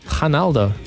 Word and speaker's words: Ronaldo: Well, in Portuguese, they don't actually pronounce the R's, eh? They pronounce it as an H. – Ronaldo.